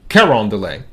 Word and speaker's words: Carondelet: I believe I said that right, Carondelet, almost sounds French.